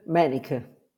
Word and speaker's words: Manniche: How do you say it? – Yes. Vibeke Manicke. – Vibeke Manicke. – Well, you're very close, very close.